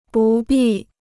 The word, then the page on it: 不必: bù bì